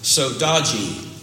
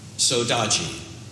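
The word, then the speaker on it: saudade: It's a Portuguese word and a Galician word from Spain, and it's pronounced sodaji, sodaji.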